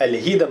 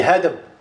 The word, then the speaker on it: Аль-Хидм: Хафиз ибн Хаджар, рахим Аллах Таля, в своей книге «Исаба» говорит «Аль-Хидм», то есть правильно говорит «Аль-Хидм», не «Хадм», как некоторые говорят, «Аль-Хидм», «Аль-Хидм».